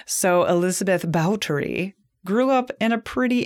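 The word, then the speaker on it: Báthory: So Elizabeth Bathory, that is actually not the correct way to pronounce her name, but it kind of sounds interesting in the way that she has been remembered throughout history as bathing in the blood of virgins, right? – Bathory. – But actually, in Hungarian, it's pronounced Bautory.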